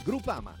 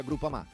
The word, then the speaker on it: Groupama: Groupama. Ah no, si pronuncia Groupama.